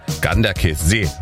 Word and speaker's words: Ganderkesee: Und dann noch solche schönen Ortschaften wie Ganderkesee und Ringau, ja. Herzlichen Dank nochmal für die Aussprache-Tipps, die schnell reinkamen von unserem Radioarzt Jürgen in Gorch, der mir gleich geschrieben hat, Ganderkesee wird das ausgesprochen.